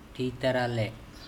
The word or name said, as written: Thitarale